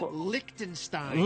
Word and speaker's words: Liechtenstein: How do you say it? – Liechtenstein. – Liechtenstein. – Oh, Liechtenstein. Yeah.